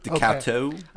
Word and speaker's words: Decoteau: For the listeners out there, it's spelled DECOTEAU. – Dakota. – Okay, that's a French spelling.